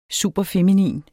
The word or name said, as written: superfeminin